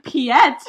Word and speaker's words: Piet: Well, congratulations, Pete. – Pete? – I've been calling him Piet. – Yeah, and you can pronounce it that way, I'm pretty sure.